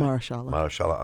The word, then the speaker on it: Mahershala: I think that Mahershala Ali, I say his name correctly? – Right? Mahershala Ali.